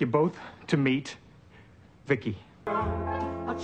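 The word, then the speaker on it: VICI: V-I-C-I, I, I pronounce it Vicky.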